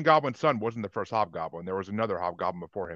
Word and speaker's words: Hobgoblin: Hobgoblin.